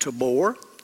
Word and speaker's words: Tabor: But if you're looking across the valley, you will see on your left a very rounded mound called Mount Tabor, we would call it. They would pronounce it Tabor.